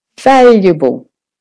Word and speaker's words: valuable: And then you just do the T-E-M, short and quick. System. Valuable. For valuable, the vowel is the stressed syllable. So that's the one you're going to make louder and longer. Valuable. Valuable. The rest gets done relaxed and quick.